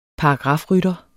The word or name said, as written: paragrafrytter